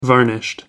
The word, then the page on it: varnished: American English